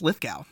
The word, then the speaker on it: Lithgow: And just so we have it all set and straight before we get started, it's pronounced Lithgow.